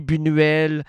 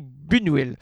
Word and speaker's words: Buñuel: J'ai Louis Malle, j'ai Louis Buñuel, j'ai... Avez-vous comment j'ai prononcé Louis Buñuel? Pas Louis Buñuel, c'est Louis Buñuel.